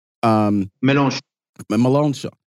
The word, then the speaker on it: Mélenchon: One of the organizers, and Reese brought up the marches, one of the organizers, and I'm hoping I'm getting his name right, Jean-Luc Mélenchon. – Mélenchon.